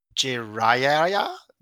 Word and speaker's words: Jiraiya: Like, I brought Jiraiya, like, I don't know how to pronounce that. – Jiraiya, yeah. – Oh, go again. – Again? – Jiraiya? – Yes, that's the dude.